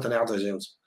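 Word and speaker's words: Tonnerre 2 Zeus: No ha cambiado la pronunciación, simplemente le han puesto DE e por un 2. En francés sigue siendo Tonnerre de Zeus.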